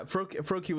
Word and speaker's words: Froakie: Yeah, Froakie, was like rhyming with low key for me.